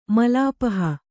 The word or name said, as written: ಮಲಾಪಹ